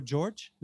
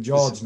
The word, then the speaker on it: Jorge: Caio Jorge. Is that how you say that? – I think so. – It's Portuguese, isn't it? So it'll be Jorge, not Jorge.